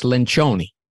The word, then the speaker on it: Lencioni: But the actual Italian pronunciation is Lencioni. – Lencioni, right? – I'm not correcting you, Jordan.